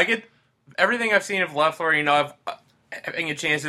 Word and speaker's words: Loeffler: Next on the list was Scott Loeffler. That's how you say it, right? – Yep. – Loeffler.